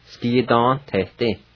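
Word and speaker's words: sgidanvtedi: And let me say the V with the N is silent. Ski-don-na-te-ti. Ski-don-te-ti. Ski-don-te-ti. Just say the N sound.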